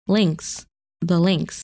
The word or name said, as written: Lynx